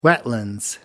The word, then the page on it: wetlands: British English